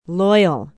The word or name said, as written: Loyal